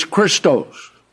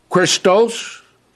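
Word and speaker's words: Christos (Χριστός): That doesn't mean we're not pronouncing the name of Christ correctly, because in the Greek, the name of Christ could be pronounced Christos, Christou, Christo, and Christon.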